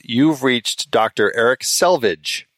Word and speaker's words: Selvig: And I think that's how you pronounce his last name, Selvig.